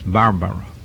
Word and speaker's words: Barbaro: Again, this is the correct pronunciation, I understand. I often say Barbaro, but Vignero, one of the contributors to the forum, tells me that it is Barbaro.